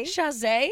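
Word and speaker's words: Chasez: But I always thought that J.C. Chaz? – Chazé. Yeah. – That's how they say it? – Almost positive.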